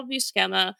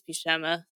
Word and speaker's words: Buscema: The writer is Tom DeFalco, and the penciler is Sal Buscema. Buscema, I think it's Buscema. Sorry, I said it wrong the first time.